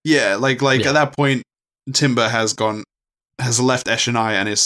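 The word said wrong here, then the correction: Timbre: It is Tambor, though. I looked up the pronunciation, unfortunately.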